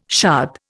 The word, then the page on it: shad: Indian